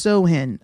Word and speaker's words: Samhain: All right, so the ancient Celtic festival of Samhain, it's pronounced Sohin, I think that's how you say it.